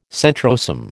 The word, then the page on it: centrosome: American English